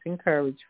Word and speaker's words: Inkerij: He now heads Encourage, I-N-K-E-R-I-G-I-J, I'm sorry, let me do that again, I-N-K-E-R-I-J, Encourage, is pronounced Encourage Foundation.